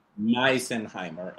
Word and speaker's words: Misenheimer: Misenheimer.